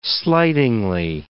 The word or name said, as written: slightingly